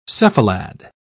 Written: cephalad

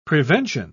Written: prevention

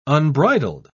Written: unbridled

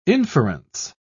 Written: inference

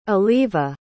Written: aliva

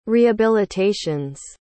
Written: rehabilitations

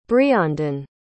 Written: breandan

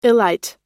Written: illite

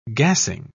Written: gassing

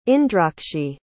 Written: indrakshi

